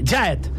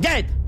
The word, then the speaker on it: gyatt: G-Y-A-T-T. Jaet. – Que no és una cadena d'hotels. – Diuen els americans que és una deformació de la frase God damn it. – God damn it. – Pronunciada molt ràpid i amb sorpresa. Acaba sonant com Jaet.